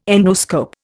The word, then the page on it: endoscope: Indian